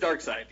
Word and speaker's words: Darkseid: Oh, no, it is Darkseid.